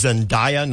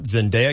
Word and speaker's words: Zendaya: It is Zendaya, not Zendaya, because I've heard both. – I've heard both, too, and I am constantly confused. I don't know if it's Zendaya or Zendaya, okay.